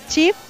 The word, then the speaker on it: Cheaf: Es Chief, C-H-E-A-F. – ¿Otra vez? – C-H-E-A-F. – Listo, así Chief.